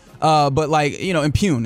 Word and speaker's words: impugn: And again, this is not to, impung, I guess that's the right way to pronounce that word, which I don't remember. Impugn is the word I was looking for.